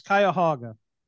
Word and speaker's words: Cuyahoga: Being able to pronounce Cuyahoga. Most people can't do that. – Yeah. Cuyahoga.